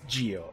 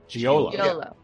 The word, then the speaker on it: Giolo: Giolo. – Giolo. – Giolo. – Emphasis on the middle syllable, if it helps.